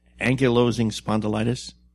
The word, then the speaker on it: ankylosing spondylitis: and I may not even be pronouncing it correctly, but it's ankylosing spondylitis. – That's the right way to say it.